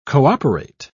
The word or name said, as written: cooperate